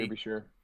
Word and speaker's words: Derbyshire: So there was a run on the Derbyshire, is that how you say it? Derbyshire Building Society. – Derbyshire. Yeah.